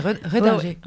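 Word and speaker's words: Rendinger: Oui, c'est ça, c'est Redinger.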